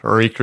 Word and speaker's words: Reek: Reek, reek, reek. It rhymes with freak.